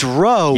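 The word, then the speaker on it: Ro: It's not Roman, which is like gladiators in the Colosseum. – Aggressive. – It's Roe.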